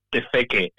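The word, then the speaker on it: Te Wheke: I hope I'm pronouncing it right. W-H-E-K-E, Tefeque.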